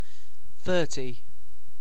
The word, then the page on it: thirty: British English